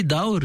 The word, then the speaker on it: Dawr: Daour, comment on prononce D-A-W-R. – Oui, Daour, c'est une forme.